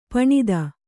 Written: ಪಣತಿ

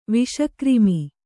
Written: ವಿಷಕ್ರಿಮಿ

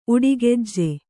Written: ಉಡಿಗೆಜ್ಜೆ

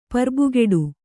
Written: ಪರ್ಬುಗೆಡು

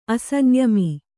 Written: ಅಸಂಯಮಿ

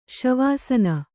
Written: ಶವಾಸನ

